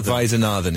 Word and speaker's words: Vaidyanathan: Before we do it again, what is her proper pronunciation as you understand it? – Regini Vaidyanathan is... – Vaidyanathan. – Vaidyanathan is how you want to be saying right. it, I think.